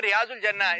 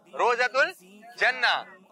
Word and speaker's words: روضۃ الجنہ: Picture ہے روضۃ الجنہ, جس کو لوگ بولتے ہیں ریاض الجنہ, اس کا صحیح تلفظ ہے روضۃ الجنہ.